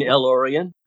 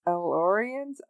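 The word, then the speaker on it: El-Aurian: You mean Elorian? – Elorians? Oh, okay. Elorian. That makes more sense. It just doesn't look like the way it's spelled, I guess.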